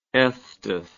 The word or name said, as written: estes